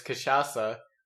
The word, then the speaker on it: cachaça: Um, this one is cachaça, which I pronounced correctly this time.